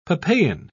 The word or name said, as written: papain